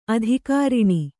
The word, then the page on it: ಆದಿಕಾರಣ: kannada; ādikāraṇa